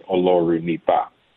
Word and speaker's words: Olorunipa: It's close. The last syllable is with an A, so it's Olorunipa.